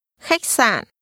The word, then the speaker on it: khách sạn: Khách sạn. sạn. Khách. Khách sạn. Khách sạn. – Hotel is pronounced with a high rising tone. – Khách and a low falling broken tone. – Sạn. Khách sạn.